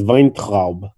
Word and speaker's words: Weintraub: Like in Yiddish, should be pronounced Weintraub.